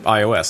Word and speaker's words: iOS: Är det rätt uttal förresten? Uh, ja, om du frågar Steve Jobs eh, så han uttalade det typ iOS.